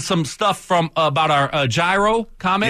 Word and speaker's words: gyro: It's Greek, so naturally the spelling isn't familiar to you guys, but indeed the proper pronunciation is gyro.